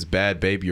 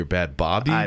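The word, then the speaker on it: Bhad Bhabie: I don't even know how to pronounce if it's Bad Baby or Bad Bobby because there's two H's in it. – I think it's Bobby because I would assume it's supposed to sound like Barbie.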